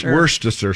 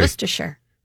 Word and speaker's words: Worcestershire: Worcestershire. – Worcestershire. – Yeah. – It's spelled W-O-R-S-T, which is worst. – Worst. – Right? – Mm-hmm. Worcestershire. – And then you would say E-R, Worcestershire.